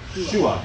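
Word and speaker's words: Shua (שׁוּעַ): Shu, this is an SH, and then an A. Shua, if you're reading it that way. Shua.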